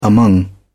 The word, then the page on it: among: British English